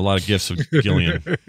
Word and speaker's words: Gillian: Hey, Gillian Jacobs pronounces it with a hard G from Community.